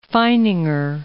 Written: Feininger

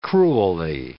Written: cruelly

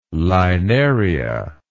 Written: linaria

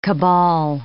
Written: cabal

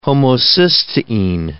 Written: homocysteine

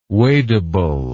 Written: wadable

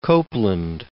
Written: Copland

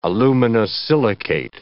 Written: aluminosilicate

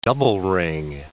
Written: double-ring

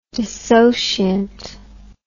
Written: dissociant

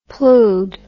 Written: ploughed

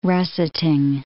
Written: russeting

